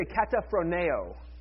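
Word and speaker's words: kataphroneo (καταφρονέω): Everybody say kataphroneo.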